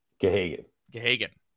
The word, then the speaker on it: Gahagan: I wouldn't say he's the backup because he's kind of sharing the load with Parker Gagan. Is that how you say it? – Gahagan. Yeah. – H was silent.